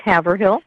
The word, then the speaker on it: Haverhill: I'm calling you in Haver Hill, Iowa, correct? – Yeah, it's actually pronounced Haverhill.